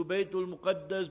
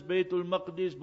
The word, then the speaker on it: Baytul Maqdis: Lo and behold, Sayyidina Umar radiallahu ta'ala anhu comes from Medina Munawwara there to Al-Quds, to Baytul Muqaddas, Baytul Maqdis, both ways you can pronounce it.